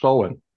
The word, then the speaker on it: Samhain: oktober nu så är det en sabbat som stavas Samhain men uttalas Soen.